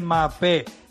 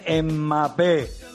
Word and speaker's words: Mbappé: Mbappé no se dice Mbappé, se dice Mbappé.